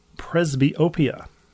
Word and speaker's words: presbyopia: This is a treatment for presbyopia.